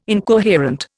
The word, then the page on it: incoherent: Indian